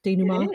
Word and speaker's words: denouement: Is that how you say it? Denouement? – It is.